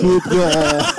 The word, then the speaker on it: croupier: Croupier. No, you don't pronounce the P.